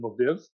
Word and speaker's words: Lviv: She grew up in Lviv, is how you say it.